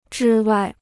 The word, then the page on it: 之外: zhī wài